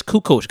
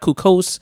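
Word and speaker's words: Kukoč: And it's Kukoc, guys. It's not Kukos. It's not Kukak.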